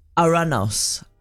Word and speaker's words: Ouranos: So Uranus was named after ancient Greek sky god, which is like Uranos. Like it's spelled O-U-R-A-N-O-S.